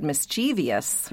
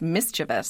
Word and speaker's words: mischievous: I'm sure I would have spelled it with a V-I-O-U-S instead of a V-O-U-S. But I remember sitting at my desk at Merriam-Webster and looking at this word and realizing, oh, there is no I-O-U-S. It's mischievous.